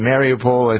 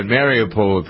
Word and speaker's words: Mariupol: And the only city that really has gone, they've all been destroyed, but isn't it, how do you pronounce it, Mariupol? I think that's how you pronounce it. – Yes. – That's the one.